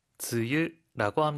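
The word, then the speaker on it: 즈유 (梅雨): 일본어로는 뭐라고 읽죠? – 즈유라고 합니다.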